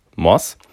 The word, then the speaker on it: Moss: Und ähm, Elizabeth Moth, keine Motte, Elizabeth Moss,